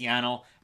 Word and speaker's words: Yanal: And yes, we know his first name, what it rhymes with, flannel. It's Yanal.